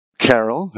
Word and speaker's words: Carroll: And your name is pronounced Carroll, not Carell. – Carroll, that's correct.